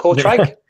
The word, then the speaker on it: Kortrijk: But Kortrijk? – Yes, it is.